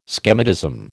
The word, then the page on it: schematism: American English